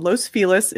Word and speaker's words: Los Feliz: If you guys aren't familiar with us, we're in the neighborhood called Los Feliz. It is pronounced that way, though there is much debate about that, in Los Angeles, California.